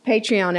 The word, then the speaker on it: Patreon: Also watch our Patreon episode. Did I say it right? – Yes. – Thank you. – She didn't say Patron.